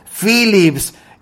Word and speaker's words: Philips: En toda Europa, en Estados Unidos, en cualquier lugar, la PH se pronuncia como F, Philips.